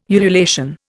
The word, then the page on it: ululation: Indian